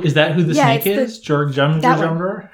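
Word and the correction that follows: Jörmungandr: It's the Jormungandr worm, I think is how you say it. – Nice. – I think it would make it a Jormungandr. – Jormungandr, thank you.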